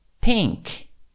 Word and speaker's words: pink: Pink. Pink. Lembra de todas as vezes que nós falamos aqui sobre a pronúncia da letra P, a explosiva?